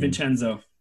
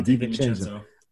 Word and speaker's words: DiVincenzo: DiVincenzo. – Yeah, DiVincenzo.